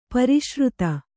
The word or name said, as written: ಪುರುಷಾರ್ಥ